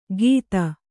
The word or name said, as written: ಕಿತ